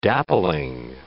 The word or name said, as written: dappling